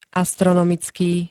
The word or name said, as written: astronomicky